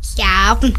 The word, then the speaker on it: Cavan: How do you say Cavan? – Cavan. – Cavan. Have I got it right? – Yeah.